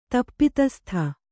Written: ತಪ್ಪಿತಸ್ಥೆ

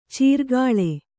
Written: ಚಾರ್ಕಲ

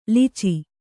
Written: ಲೊಚ

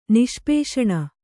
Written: ನಿಷ್ಪೇಷಣ

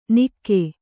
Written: ನಿಕ್ಕೆ

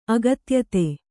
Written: ಅಗತ್ಯತೆ

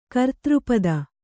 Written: ಕರ್ತೃಪದ

